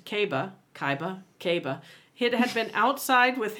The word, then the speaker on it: Kaiba: No, that was me attempting to figure out how to pronounce. K-A-I-B-A. – Kaiba.